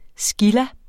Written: scilla

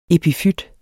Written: epifyt

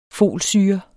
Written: folsyre

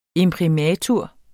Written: imprimatur

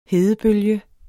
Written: hedebølge

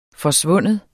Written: forsvinde

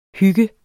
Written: hygger

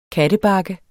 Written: kattebakke

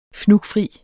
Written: fnugfri

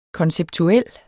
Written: konceptuel

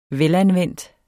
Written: velanvendt

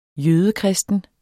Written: jødekristen

